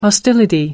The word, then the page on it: hostility: American English